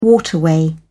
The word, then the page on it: waterway: British English